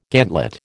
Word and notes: gantlet: American English

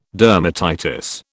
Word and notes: dermatitis: British English

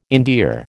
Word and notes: endear: American English

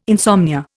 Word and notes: insomnia: Indian

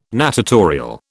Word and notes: natatorial: British English

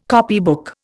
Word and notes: copybook: Indian

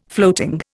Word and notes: floating: Indian

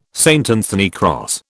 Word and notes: St Anthony cross: British English

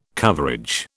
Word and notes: coverage: British English

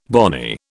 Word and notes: bonny: British English

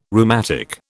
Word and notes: rheumatic: British English

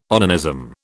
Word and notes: onanism: British English

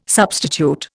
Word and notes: substitute: Indian

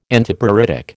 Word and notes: Antipruritic: American English

